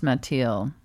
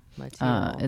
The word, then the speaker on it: Mathilde: Well, it's similar to, I guess, Matilda, but but it's true. – Pronounced Mathilde. – Mathilde. – It's French.